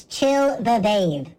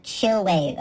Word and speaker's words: Chill the Vave: And it's Chill the Vave, not Chill Wave, okay? It's two V's put together instead of a W. I refuse to pronounce it Chill Wave.